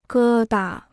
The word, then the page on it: 疙瘩: gē da